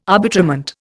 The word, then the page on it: arbitrament: Indian